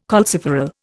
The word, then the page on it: calciferol: Indian